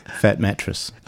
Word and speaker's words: Fat Mattress: and we're now going to have Fart Matras coming up. – Are we? – Isn't that how you pronounce it? – No. What are we? Where am I? – Fat Mattress.